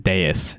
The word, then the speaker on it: dais: Dais. I think they pronounce it dais. It's like D-A-I-S.